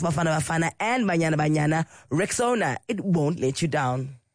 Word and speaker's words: Rexona: It's pronounced Rexona, spelled R-E-X-O-N-A.